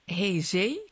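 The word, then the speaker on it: Heze: En nou heeft de verkeerspolitie van de stad Hezee, ik hoop dat ik het goed uitspreek. – Oké. – Hoe spreek je het uit? – Ja, goed.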